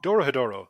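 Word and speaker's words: Dorohedoro: Now, what weapon, tool, or useful item would you take with all of the volumes of Doro Doro? Is that how you say it? – Doro Hedoro.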